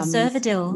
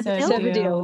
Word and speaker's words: Cervidil: I had the Cetaphil, is that how you say it? – Yeah. Cervidil. – Yeah. Cervidil. That's it.